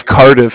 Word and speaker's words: Cardiff: it's not Bank of the Cow. It's Bank of Cardiff, and you spell it C-A-R-D-I-F-F.